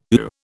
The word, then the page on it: ooh: British English